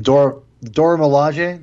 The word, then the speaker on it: Dora Milaje: Yeah, you know, I the whole Dorja, like, oh my god, Dora Milaje, did I say it right? – You got it that time.